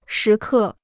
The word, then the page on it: 时刻: shí kè